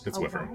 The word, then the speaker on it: wyvern: It's wyvern.